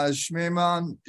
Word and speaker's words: Schmemann: I mean, I know Schmemann. Am I saying that right? – Yes.